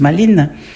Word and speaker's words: Maline: La jeune Maline, ça s'écrit comme Malin, mais je pense que ça se prononce Maline, une adolescente, n'est-ce pas, fait une drôle de découverte.